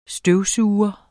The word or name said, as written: støvsuger